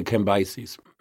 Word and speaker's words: Cambyses: And I've heard it pronounced Cambyses. – Cambyses. Is that what did I say? Cambyses? – Yeah. – I would say Cambyses. So let's say Cambyses.